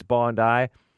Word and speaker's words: Bondi: It looks like Bondi, but it's Bondi. You know, those Australians, they pronounce things funny.